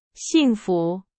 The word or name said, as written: 幸福